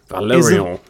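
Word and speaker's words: Valérian: The reason for the name Valerian, because it's French. That's the proper French pronunciation.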